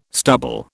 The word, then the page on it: stubble: British English